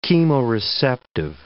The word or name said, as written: chemoreceptive